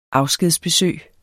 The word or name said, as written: afskedsbesøg